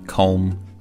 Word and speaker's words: comb: Other examples I can think of would be comb, lamb, thumb. – 再比如说 comb, 梳头发 C-O-M-B, 最后这个 B 不发音。